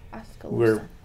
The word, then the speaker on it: Oskaloosa: Yeah, there is no K in it. I was mispronouncing it. – Oh, thank you. Oskaloosa.